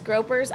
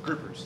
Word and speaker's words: groupers: And there's not much room between you and the sharks and the fish and the, is it groupers? Gropers? I always say it wrong. – Groupers. – Groupers. I say gropers every time.